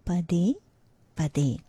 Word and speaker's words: pade pade: The pronunciation is like this: pa as in pun, pun, pa, and then de is pronounced very softly, like the English word they, de, but with a gentle the, pade, pade. The de is stressed equally, both ways, pade, pade. So one more time, pade, pade.